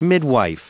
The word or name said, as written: midwife